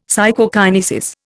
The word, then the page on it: Psychokinesis: Indian